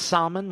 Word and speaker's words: Salman: It's like his name is pronounced Salmon.